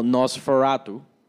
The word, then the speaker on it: NOS4A2: Y él también tiene, me encanta cómo es que se escribe, porque es NOS4A2, que pues en inglés eso lo puedes decir como Nosferatu.